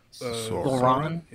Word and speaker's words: Sauron: Well, it almost looks like the Sorin. That's not his name. How do you say the guy's name? – Sauron.